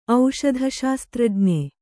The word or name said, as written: ಔಷಧಶಾಸ್ತ್ರಜ್ಞೆ